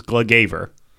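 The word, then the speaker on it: Gluggaveður: Yeah, and of course this is Glugaver. – Glugaver.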